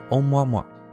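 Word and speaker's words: Oumuamua: 当然啊，这是音译过来的名称啊，实际上它的发音应该是欧莫莫，啊，倒也不用真的摸出来啊，只要把啊的发音带出来就行。那怎么会有一个这么好玩的名字呢？那是因为啊，这个天体是由夏威夷天文台所发现的，所以欧莫莫是夏威夷语啊，意思就是侦察兵或者是送信者的意思。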